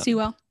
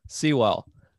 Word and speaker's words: Sewell: I think they're going to pick Sewell. Is that how you say his name? Sorry. – Yeah, no. Sewell. – Sewell.